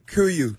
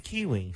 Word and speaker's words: Kuiu: Kuyu. – He doesn't know how to pronounce it. – K-U-I-U.